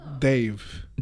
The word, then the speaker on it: Dev: No, but there's no D sound in India, so it's Dave.